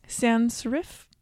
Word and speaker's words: sans serif: sans serif.